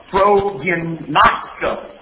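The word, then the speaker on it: Prognosko (προγινώσκω): P-R-O-G-I-N-O-S-K-O. Prognosko. Nosko. I'll say it correctly that way.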